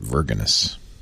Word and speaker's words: Vergennes: V-E-R-G-E-N-N-E-S, Vermont. – Vergen, I know, very French. – But knowing Americans, they probably pronounce it Vergenes. – Vergenes.